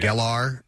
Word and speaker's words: Gellar: Gellar.